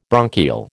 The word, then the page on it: bronchial: American English